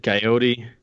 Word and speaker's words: coyote: Why do we pronounce it coyote with a long T? Well, my best guess is the cartoons.